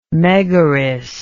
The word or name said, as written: megaris